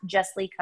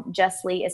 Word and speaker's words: Jesslee: All of my socials are at Jesslee Country. Jesslee is spelled J-E-S-S-L-E-E, all one word.